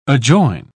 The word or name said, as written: adjoin